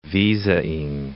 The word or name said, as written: visaing